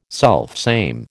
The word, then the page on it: selfsame: American English